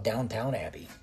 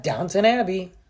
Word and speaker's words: Downton Abbey: First, I used to think it was called Downtown Abbey. I kept on going, Downtown Abbey? The heck is that about? And then I was like, oh, oops. I'm saying it wrong.